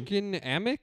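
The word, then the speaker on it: Mädchen Amick: Mackin Amick. Whoa, what the fuck? – Yeah, good luck pronouncing her name. – Mackin Amick? – Yeah.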